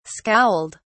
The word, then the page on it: scowled: British English